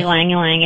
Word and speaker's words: Ylang Ylang: Lang Ylang. – What? – So it's spelled Y-L-A-N-G. – Okay. – Y-L-A-N-G. It just repeats itself. – Okay. – Ylang Ylang.